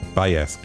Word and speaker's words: Baez: Baez, spricht man das, glaube ich. – Baez.